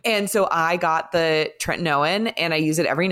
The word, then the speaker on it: tretinoin: Tretinoin.